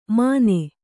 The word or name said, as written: ಮನೆ